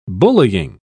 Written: bullying

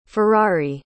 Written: ferrari

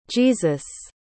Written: Jesus

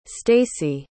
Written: staci

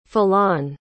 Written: falan

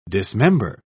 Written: dismember

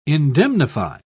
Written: indemnify

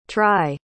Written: tri

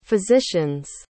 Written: physicians

